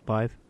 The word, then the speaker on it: lithe: Lithe, is that how you say it? Lithe? Lithe? – Lithe.